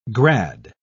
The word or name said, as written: grad